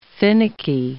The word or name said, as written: finicky